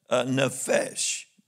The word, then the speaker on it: nephesh: And that word is nephesh. Nephesh. That's the way it's pronounced. N-E-H-F-E-S-H.